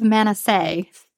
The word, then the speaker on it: Manasseh: And in chapter 22, Joshua tells the tribe of Reubenites, Gadites, and half of Manasseh. I hope I'm saying that right. – That's how I pronounce it.